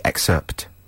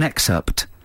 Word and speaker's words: excerpt: Next time you say excerpt, can you say excerpt? – Is that how it's spelt? Excerpt? – Yeah. I want to hear the P.